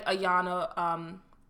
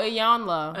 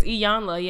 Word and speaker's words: Iyanla: Oh, Ayanla. Ayanla. – Is it La? – It's Ayanla, yeah. Iyanla. Ayanla Fixed My Life.